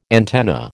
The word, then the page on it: antenna: American English